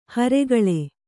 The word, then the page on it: ಹಿರಿಕೊಳ್: hirikoḷ